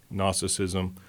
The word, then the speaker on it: Gnosticism: Starts with a silent G. Gnosticism, that there's some hidden knowledge and some divine truths that God can't reveal to everybody at the same time.